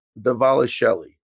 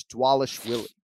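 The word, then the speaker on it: Dvalishvili: The V's are pronounced as W's, so it's Dwalish Willie.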